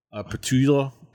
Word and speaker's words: Petula: And those are our thoughts on the ultimate Petula Clark. Petula? Petula. – To add some extra syllables Petula. – In there. Petula.